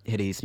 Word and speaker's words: Hiri's: And yeah, you can also catch Hiri's music, which is H-I-R-I underscore music.